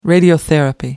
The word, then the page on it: radiotherapy: American English